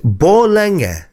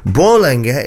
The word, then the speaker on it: Borlänge: He says, Borlänge is how it's pronounced. Don't even try the up-down Swedish accents, guy. I lived there for years and never got my head stroke tongue round it. Borlänge is a nice little town in Dalarna.